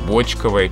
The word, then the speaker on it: бочковое: Давайте вернемся к правильной версии и скажем, что правильный литературный нормативный вариант – бочковое пиво.